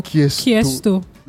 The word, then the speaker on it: Qiesto: Kiesto. It looks like Kiesto. Q-I-E-S-T-O.